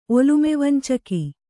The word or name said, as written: ಒಲುಮೆವಂಚಕ